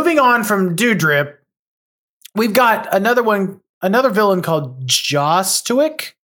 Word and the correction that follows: Jozotic: No, Jozotic. – How do you say Jozotic? Jozotic. Yes. Jozotic.